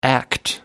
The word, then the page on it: act: American English